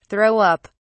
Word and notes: throw up: British English

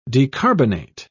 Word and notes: decarbonate: British English

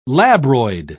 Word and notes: labroid: British English

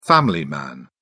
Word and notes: family man: British English

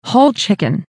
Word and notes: whole chicken: American English

